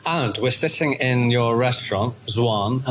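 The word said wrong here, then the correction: Xuan: Have I pronounced that correctly? – Soon. – I wasn't even close. – It's basically like summer, like soon.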